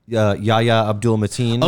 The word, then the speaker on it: Yahya Abdul-Mateen: Also, Yahya Abdul-Mateen.